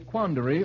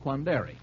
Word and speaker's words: quandary: How about a quandary, or as I believe in Great Britain, they sometimes pronounce it, quandary. – Quandary in the rhyme. There. – Quandary in the rhyme, and what is the rhyme, Adams? – I'm in a nice quandary. – Yes, but what rhymes with it? – Seminary.